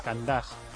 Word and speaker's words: Candás: Candás. Con acento en la A de Candás.